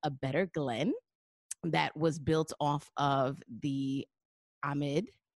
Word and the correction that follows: Ahmaud: Am I saying his name right? Ahmad. Ahmad. – Ahmad. – I don't know why I always want to say Ahmed.